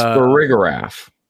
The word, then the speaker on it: Farigiraf: It's Farigiraf.